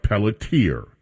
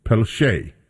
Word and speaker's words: Pelletier: It looks like Pelletier, and that is the way that many people would pronounce it. However, I happen to know that there's a French pronunciation that is also Pelche. P-E-L-L-E-T-I-E-R, Pelche.